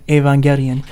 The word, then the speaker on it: Evangelion: And, of course, I also got into Evangelion, which is very good. Or as it's pronounced in Japan, Evangelion.